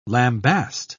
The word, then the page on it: lambast: British English